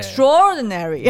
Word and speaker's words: extraordinary: 哦，extraordinary 哦，重音在 extraordinary。 OK，好好好好。